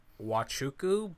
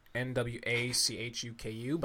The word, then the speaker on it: Nwachuku: Wachuku, but it's Wachuku? – spelled Nwachuku, but the N is silent. Don't ask me why.